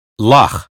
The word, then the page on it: loch: American English